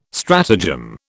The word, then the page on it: stratagem: British English